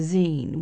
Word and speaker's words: zine: So it's Hamilton's creative community, the zine, which rhymes with scene.